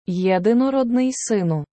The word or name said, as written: Єдинородний Сину